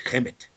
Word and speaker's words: Kemet: It's spelled Khemet, Khemet. Anyway, it's Kemet, Kemet.